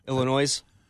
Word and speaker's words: Illinois: Illinois. – Yeah. – Yeah, I believe so. – So dumb. – That's a S is silent.